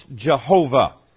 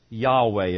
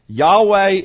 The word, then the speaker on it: Yahweh: And they guessed and said, we think it's pronounced Jehovah. But you know, later they learned Jehovah was not the right pronunciation of those letters. The real pronunciation is Yahweh. Have you ever heard the word Yahweh? Yahweh is the proper pronunciation. We do use the word Jehovah also, but Yahweh is more correct.